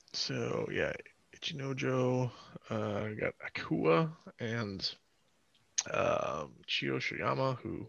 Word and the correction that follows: Chiyoshoma: Yeah, I said it right. Chiyoshoma. There we go. I was adding an extra syllable at the end there.